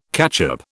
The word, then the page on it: catchup: British English